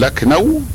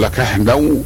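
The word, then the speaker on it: لكهنو: فبعض الناس يقول لكنو كما هو النطق المعتاد وبعضهم يقول لكحنو ولا يخرجها الا واحد متمرن على اخراج هذه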